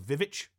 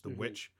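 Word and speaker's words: The VVitch: He did the I like to call the Vivitch, even though it's pronounced the witch.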